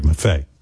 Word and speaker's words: Maffei: It's actually Greg Maffei. Like Tina Fey.